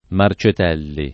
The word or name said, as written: Marcetelli